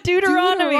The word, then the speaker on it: Deuteronomy: Deuteronomy? Am I saying that right? – No. Say doot. – Oh, I thought you were saying dude. – No, Deuteronomy. – Deuteronomy, like the science of dudes. Deuteronomy. – No. D-E-U-T-E-R-O-N-O-M-Y.